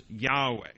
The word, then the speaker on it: Yahweh: we believe that it's best pronounced Yahweh.